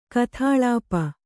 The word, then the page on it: ಗತಲೇಪ: gata lēpa